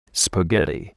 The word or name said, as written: spaghetti